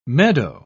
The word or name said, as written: meadow